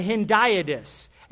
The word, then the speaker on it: hendiadys: Okay, for those of you taking notes, it's spelled H-E-N. H-E-N, it's like hen, the chicken. D-I-A, like dia in Espanol. Okay, D-I-A. D-Y-S, the beginning of dysfunction, I don't know. Okay, hendiadys, H-E-N-D-I-A-D-Y-S.